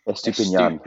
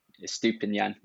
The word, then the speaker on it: Estupiñán: Estupiñán. – Estupiñán, yeah, there we go.